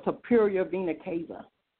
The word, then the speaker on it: superior vena cava: Because the largest vein in my body, superior cape, I'm trying to make sure I pronounce it right, the superior vena cava is the largest vein in your body.